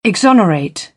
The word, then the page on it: exonerate: British English; ɒ